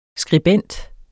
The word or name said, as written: skribent